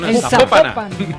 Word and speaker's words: Zapopan: Oiga, nos escriben los oyentes y que no se pronuncia Zapopan, sino que se pronuncia Zapopan. – Zapopan.